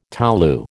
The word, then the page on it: tolu: American English